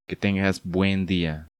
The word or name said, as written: Que tengas buen dia.